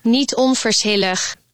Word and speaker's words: Niet onverschillig: Niet onverschillig. – How's my pronunciation? Was that better? – Yes, it's perfect.